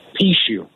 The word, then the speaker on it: Pucheu: In Mississippi, your last name is spelled P-U-C-H-E-U. How do they pronounce that in Mississippi? – Pichu.